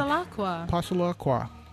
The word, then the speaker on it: Passalacqua: And we've got a matching gift challenge in the amount of $500 from Ed Hardy and Francesca Passalacqua. I hope I'm pronouncing that right. – Passalacqua. – That sounds kind of French.